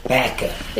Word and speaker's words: Vacaville: How do you say it? – Vacaville. – Okay. – Vacaville. – Yeah. Vacaville. – Oh, okay. – The back of the car.